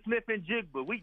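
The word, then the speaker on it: Smith-Njigba: How do you pronounce it? – Jackson Smith and Jigba. That's his name, Johnny.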